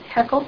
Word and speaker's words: Haeckel: Oh, I just wanted to mention to you, you know, in the footnote down there, he talks about Haeckel. Is that how you say it? – Haeckel.